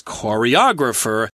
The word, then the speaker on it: choreographer: Choreograph as a verb has the accent on the first syllable, choreograph, whereas the person is choreographer, the accent is a little later in the word.